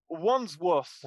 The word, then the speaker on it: Wandsworth: Wandsworth.